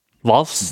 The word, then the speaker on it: Valves: Geschrieben wird äh, V-A-L-V-E-S, also Wave. – Valves. – Valves. Valves. Röhren.